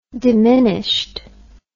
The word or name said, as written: diminished